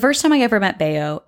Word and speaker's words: Bello: We called this cat Bayo, spelled B-E-L-L-O, but pronounced Bayo, like the suave feline he was.